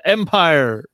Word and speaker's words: Empyre: We got the announcement of Marvel's big summer spectacular event of the year of 2020, and it is going to be called Empire, spelled with a Y, because there was already a comic book called Empire, E-M-P-R-I-E,